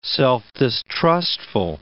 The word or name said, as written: self-distrustful